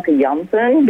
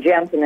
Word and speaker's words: Janssen: It's I mean, it's originally from Janssen because that's you know I'm Dutch and we pronounce the J that way. But yeah, um, Janssen is how Americans call me.